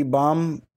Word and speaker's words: Bohm: She's on two fight skid, taking on Mandy Bohm, who is making her UFC debut, coming from Bellator. That's how they say it over there. The announcer.